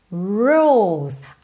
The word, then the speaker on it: rules: Rules. Atenção que como a letra E não é pronunciada, você já parte do L, que é a linguinha tá lá no céu da boca, ó, rules, pro S, tá?